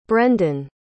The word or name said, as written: brenden